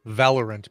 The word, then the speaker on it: Valorant: Valorant. – It really is. Valorant, because... I hate it. – Is it spelled like Valor Ant? – Yeah, Valor Ant. Like the bravest ant in the land.